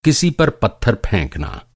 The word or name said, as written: पत्थर